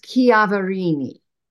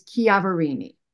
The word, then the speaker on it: Chiaverini: So the correct pronunciation is Chiaverini, yeah, like Chianti. The CH is pronounced.